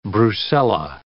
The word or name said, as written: brucella